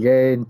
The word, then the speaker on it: Gane: Gane like Kane. Yeah. – Yeah. All right. So Cyril Gane, Tanner Bowser.